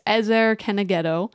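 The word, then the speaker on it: ezer kenegdo: Those word helper, or as the King James put it, helpmate, which is often how I think it is still known, is this ezer kenegedo. That's how it is said in Hebrew. I mean, that's not a great pronunciation of it, but it's pretty familiar.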